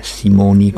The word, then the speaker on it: Simone: Sono due donne, due nomi femminili che finiscono per E, si legge Simoni quando è scritto Simone.